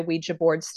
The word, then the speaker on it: Ouija: Or, well, I mean, it's, I think the J-A is generally pronounced more like Ja. So Ouija board. But as you say it fast, Ouija board, it sounds like an, you know, Ouija.